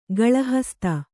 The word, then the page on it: ಗಳಹಸ್ತ: gaḷahasta